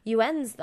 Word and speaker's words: yuan: I know Chinese dollars are called yuans though. That's how it's pronounced too. Yuan.